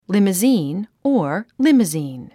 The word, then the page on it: limousine: líməzìːn; リムズィーン